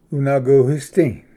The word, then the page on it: ᎤᏅᎫᎯᏍᏗ: unvguhisdi